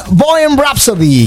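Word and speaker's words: Bohemian Rhapsody: hicieron Bohem Rhapsody, que aquí tenemos una maestra de inglés, ¿lo pronuncie bien? – Absolutamente.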